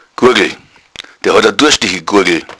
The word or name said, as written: gurgel